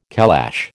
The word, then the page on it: calash: American English